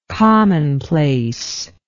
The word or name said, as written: commonplaceness